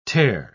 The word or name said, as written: tare